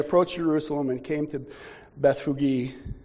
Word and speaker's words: Bethphage: Bethphage is how it's actually pronounced, means house of unripe figs.